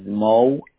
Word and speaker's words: Moe: Is Mo, M-O-E, dot Nassar, number three, at Gmail.